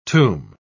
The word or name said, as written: tomb